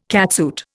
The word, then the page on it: catsuit: Indian